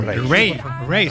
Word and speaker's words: Raye: Ray, se escribe Raye.